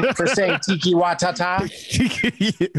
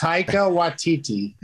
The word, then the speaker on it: Taika Waititi: For saying Tiki Watata. – That's not how it's pronounced, is it? – No, it's Taika Waititi.